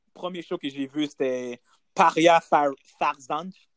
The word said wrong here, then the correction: Farzaneh: Did I say that right? – Farz c'est Far, Farzaneh. – Farzaneh.